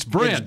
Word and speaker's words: Brent: And then he screws up Brent Urban. – Brett. I said Brett, didn't I? – It's Brent. – It's Brent. Okay.